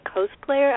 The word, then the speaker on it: cosplayer: How do you pronounce it? – Cosplayer. – Cosplayer. Okay, like costume. Yeah. That makes sense. – Yeah, costume and player all crammed together.